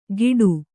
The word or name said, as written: ಗೀಟು